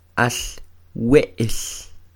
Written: al We'l

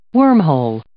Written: wormhole

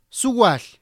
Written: suwalh

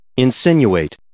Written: insinuate

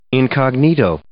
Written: incognito

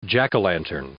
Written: jack o' lantern

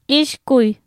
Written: ixkuy

